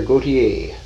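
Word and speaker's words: Gauthier: G-A-U-T-H-I-E-R. I don't know how you pronounce it. There's a French and a Belgian way of doing it. – I've heard it pronounced Gautier. – Gautier. The Belgians, more of an accent on the last syllable.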